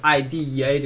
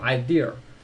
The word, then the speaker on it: idea: 大家我多说一句，大家千万不要把这个 idea 这个单词读成 idea，它不是 idea，就后面没有这样一个卷舌音，就 idea 不是 idea 好吗？